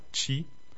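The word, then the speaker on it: ĉi: Tout ce qui commence par chi, C accent circonflexe I. On avait déjà dit que le C accent circonflexe se prononce ch en espéranto.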